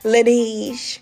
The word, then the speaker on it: Laneige: But this lip sleeping mask that Otima always brings around when she comes around to us, it's called La Neige. Am I pronouncing it wrong? I'm trying to make it more fancy. Laneige. L-A-N-E-I-G-E.